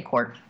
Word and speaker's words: Kohr: Let me, Archie Kaur, K-O-H-R, Kaur? – Yeah, I would say Kaur. – Kaur? Okay. – I'm going to guess the H is silent.